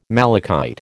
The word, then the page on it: malachite: American English